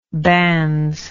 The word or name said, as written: bans